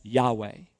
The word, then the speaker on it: Yahweh: That name that comes over, it's four Hebrew letters that we best know to pronounce as Yahweh.